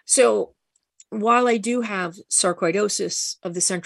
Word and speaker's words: sarcoidosis: You were diagnosed with a rare form of the disease sarcoidosis. Am I saying it right? – Yes. Thank you. Well done.